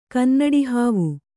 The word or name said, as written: ಕನ್ನಡಿಹಾವು